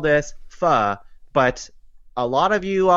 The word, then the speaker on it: pho: I've heard it all, but it's pho. That's the actual dialect of how to say it. There's accents on the end. So there's a little bit of like a rise and fall to the naming of it. It's pho.